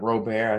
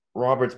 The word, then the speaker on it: Robert: Luis Robert, I've always pronounced it Robert. I thought it was pronounced like that, but apparently it's straight up pronounced Robert, which I didn't expect it to be.